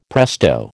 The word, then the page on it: presto: American English